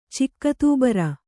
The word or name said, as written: ಚಿಕ್ಕತೂಬರ